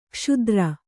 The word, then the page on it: ಕ್ಷುದ್ರ: kṣudra